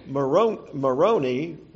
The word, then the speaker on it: Moroni: And the angel Moroni, rhymes with baloney, that Joseph Smith claimed he heard.